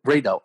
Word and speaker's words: Reidao: How do you pronounce it? You pronounce it as Radar? – Radar, R-E-I-D-A-O.